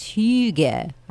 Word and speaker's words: hygge: And it's pronounced hygge. I can't even say it. You're supposed to spit when you say it, but I'm not sure where I'm supposed to spit.